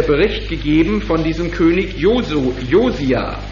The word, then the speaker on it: Josia: Josia, sieh. Josia. Also auf dem I, Josia.